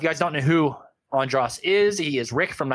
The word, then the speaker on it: Andras: Yeah, now I said it right. Andras Jones. That's how you say it.